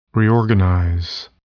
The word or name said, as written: reorganize